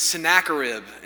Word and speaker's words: Sennacherib: Before we read, let me kind of give you a quick word of apology that I have been pronouncing the king of Assyria's name wrong this entire time. And Pastor Steve knew and decided not to tell me anyway, so I appreciate that a lot. But it's Sennacherib, and so if you need to know how to remember it, I've just been telling myself a snack of ribs.